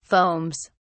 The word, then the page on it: foams: British English